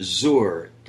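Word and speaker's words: zor (זר): And very interestingly, Junie, the word for strange in Hebrew is a word that's pronounced zor. Perhaps if we were to spell it phonetically, it would be Z-O-O-R.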